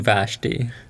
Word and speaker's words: Vashti: Queen Vashti.